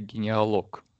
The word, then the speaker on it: генеалог: Обычно называют историю генеалог с ударением на последнюю «о» от греческого «логос».